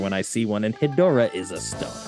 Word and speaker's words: Hedorah: It's spelled like Deborah, so maybe it is Hedorah.